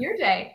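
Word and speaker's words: Iridae: I-R-I-D-A-E. I-R-I-D-A-E. How would you pronounce it? – Ear day is how you pronounce it. Ear day.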